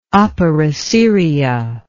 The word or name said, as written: opera seria